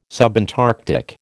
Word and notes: subantarctic: American English

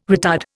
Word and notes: retard: Indian

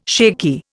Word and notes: shaky: Indian

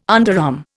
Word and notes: underarm: Indian

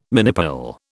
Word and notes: minipill: British English

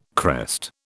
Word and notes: crest: British English

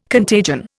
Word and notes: contagion: Indian

